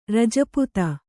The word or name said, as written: ರಜಪುತ